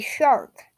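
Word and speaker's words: Chark: Okay, so DJ Shark. Wait. Yes, it is pronounced shark.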